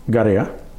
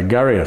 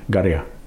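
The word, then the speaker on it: Garriya: It, even for me, because I just say garia. A lot of people say garia, so we. – How would you spell it? – G, with the rules in the book, yeah. – It's a G A double R. – Yeah. – I Y A, garia.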